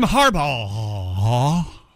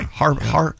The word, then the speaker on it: Harbaugh: Jim Harbaugh. – That is the correct pronunciation. – Boy, that is a lot. Yeah, there's a lot of silent letters in that, right? R? – Too many. – Harbaugh.